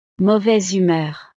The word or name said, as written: mauvaise humeur